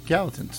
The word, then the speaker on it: Gallatin: It's gal and then Latin. – Yeah. But the L's are together. Is it like a Y like Spanish? Is it Gayton? – No, it's Gallatin. – Gallatin.